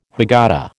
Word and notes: Haggadah: American English

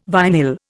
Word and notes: vinyl: Indian

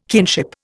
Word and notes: kinship: Indian

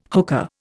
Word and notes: hookah: Indian

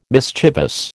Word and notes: mischievous: American English

